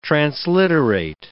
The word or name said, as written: transliterate